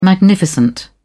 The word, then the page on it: magnificent: British English